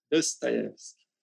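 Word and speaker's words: Dostoevsky (Достоевский): É um livro chamado O Idiota, de Fyodor Dostoevsky, como dizem os russos, né, na pronúncia russa. Dostoevsky. Enfim,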